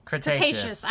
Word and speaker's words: Cretaceous: Cretaceous. – I don't know why I put an S in front of that.